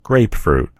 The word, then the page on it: grapefruit: American English